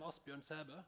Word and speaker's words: Asbjørn Sæbø: I am Asbjørn Sæbø, that O with a slash, that is an Ø, and that combination of an A and an E, that is an A, but I pronounce it E, E, in my name only.